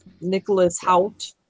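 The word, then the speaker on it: Nicholas Hoult: Nicholas Holt.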